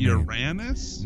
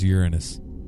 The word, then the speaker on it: Uranus: Is it Uranus? – No, it's Uranus. – Uranus? – That's how they, that's how a lot of people pronounce it when they don't want the giggles. Uranus. – Oh, okay. – We were always taught Uranus in school.